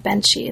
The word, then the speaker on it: Benshi: focuses mainly on Benshi. Am I pronouncing that correctly? – Yes. – Benshi, great.